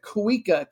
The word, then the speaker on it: Kuikka: And we have another USA connection, a gal who plays for Portland as a defender, Natalia, I'm going to pronounce it Kuika, K-U-I-K-K-A.